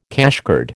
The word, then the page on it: cashcard: American English